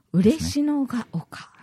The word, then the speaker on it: 嬉野が丘: これはですね、ハエバルにある嬉野が丘、はい、サマリア人病院です、ね、嬉野が丘と読むんですね、はいはい、